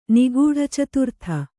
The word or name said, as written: ನಿಗೂಢಚತುರ್ಥ